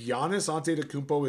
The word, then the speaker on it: Antetokounmpo: If Giannis Antetokounmpo is out, put your life on Bobby Portis.